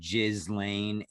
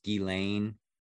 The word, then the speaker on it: Ghislaine: Well, isn't it funny how in America they pronounce it Ghislaine and she's involved with all this shit when in reality it's Ghislaine.